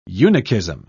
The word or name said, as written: eunuchism